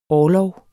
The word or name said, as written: orlog